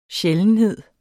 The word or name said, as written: sjældenhed